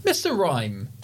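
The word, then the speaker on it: Mr. Rime: Mr. Rhyme. DC or not DC? – This is because Mr. Rhyme rhymes with Mr. Mime.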